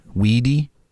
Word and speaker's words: Weide: Weedy, and it's spelled W-E-I-D-E, Weedy, Bob Weedy.